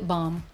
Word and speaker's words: balm: If you don't pronounce the L on an airplane, you could get in trouble. – Really? – Lip balm.